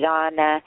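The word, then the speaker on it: Jñāna: So this is it's pronounced so many different ways, but Jan or Jana, I pronounce it Jana.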